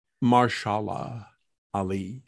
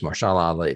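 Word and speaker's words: Mahershala Ali: Marshalla Ali. – Yeah, I'm sorry. It is Marshalla Ali, the guy that played Vector.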